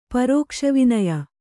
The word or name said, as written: ಪರೋಕ್ಷವಿನೆಯ